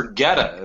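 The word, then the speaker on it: Goetta: But Cincinnati's famous for Getta. Am I pronouncing that correctly? – You're pronouncing it correctly.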